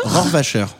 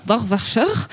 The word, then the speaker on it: Rohrwacher: Alba Rochwacher. – Rohrwacher. Rohrwacher.